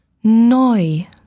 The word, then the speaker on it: neu: Neu. – New. – Neu.